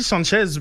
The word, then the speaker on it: Sanchez: C'est pas un ami, hispanophone, mais Alexis Sanchez, comme sans, sans la prononciation du S, c'est généralement ce que j'ai entendu.